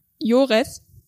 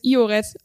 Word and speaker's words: Ioreth: Dann das i wird manchmal ausgesprochen wie ein j. Und zwar dann, wenn es am Anfang eines Wortes vor einem anderen Vokal steht. Das bekannteste Wort hier ist Joreth, was die meisten vermutlich als Ioreth aussprechen würde.